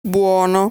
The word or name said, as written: Buono